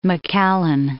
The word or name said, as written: McAllen